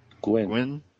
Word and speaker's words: Nguyen: Dustin Nguyen? – Gwen. – Silent N. Gwen.